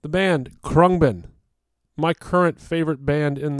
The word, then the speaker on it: Khruangbin: The band is pronounced Krungbin, which is Thai for airplane. And that's spelled K-H-R-U-A-N-G-B-I-N.